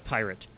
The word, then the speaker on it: Pirit: Because it's spelled P-I-R-I-T. – Right. – You know? So we'll just go with Pyrit.